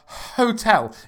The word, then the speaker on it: hotel: Some people might say an hotel, but they shouldn't really say it like that. When you think about hotels. – Wait, sorry. – You go. – You're saying hotel, like not pronouncing the H.